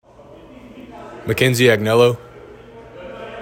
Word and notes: McKenzie Agnello: mah KEN zee ag NELL oh

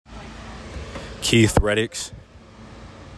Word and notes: Keith Reddix: keith red ICKS

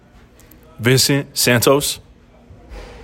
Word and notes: Vincent Santos: vihn SENT SAN tohs

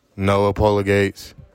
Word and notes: Noa Pola-Gates: NO ah PAUL ah GATES

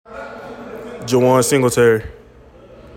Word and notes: Jawaun Singletary: joo WAHN SING gl TEHR ee

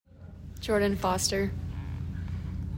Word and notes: Jordan Foster: JOR din FOSS ter

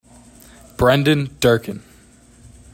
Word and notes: Brendan Durkin: BREN dihn DUR kihn

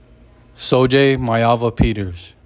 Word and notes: Sol-Jay Maiava-Peters: SO JAY MY ah vah P terz

